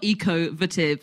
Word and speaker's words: Ecovative: Um, it's ecovative and so the pronunciation is quite difficult it's spelled ecovative and they say it's the same as the pronunciation of innovative